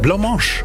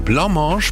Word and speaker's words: Blancmange: Da fragen wir uns eigentlich seit 40 Jahren, wie wird der Name richtig ausgesprochen? Und ich habe mir nachgeschaut. Blancmange werden sie ausgesprochen. Blancmange.